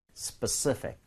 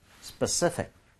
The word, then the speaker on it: specific: 근데 중요한 거 뭐냐면 강세가 중요해요. Specific. CI에다 강세 하나 찍을게요. Yes. 자, 여러분 같이 한번 발음해 보겠습니다. 거의 강세 주고요. F니까 픽이라고 하지 말고 마지막에 thick. 이렇게 가는 거 가볼게요. Specific. Specific.